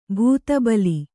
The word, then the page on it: ಭೂತಬಲಿ: bhūta bali